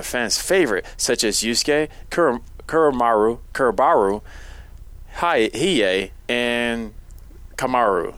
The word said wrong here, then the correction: Kurama: Did I say it right? What was that dude? – Kurama. Okay. – Eric, I was close.